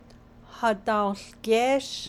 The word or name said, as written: hadaołgéésh